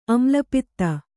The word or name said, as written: ಆಮ್ಲಪಿತ್ತ